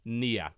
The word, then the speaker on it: Nier: Nier. It's spelled N-I-E-R.